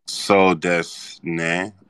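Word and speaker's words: sou desu ne: So does man. – In Japanese? – Wait, say that again? – So des ne.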